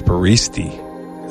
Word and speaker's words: baristi: The plural word for barista is baristi. That is pronounced ba-ri-sti.